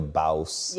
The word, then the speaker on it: Bawse: so your book, How to Be a Bawse.